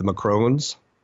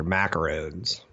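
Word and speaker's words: macaron: Back when Macron got elected, I used to make cute jokes like, oh, I love those Girl Scout cookies, the Macrons. Oh, no, those are macarons. Nah. Nothing like the proper pronunciation to ruin a good joke, right?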